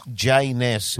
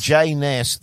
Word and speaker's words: J-Ness: It was J-Ness. – J-Ness. – Rhymes with A-Ness. – J-Ness.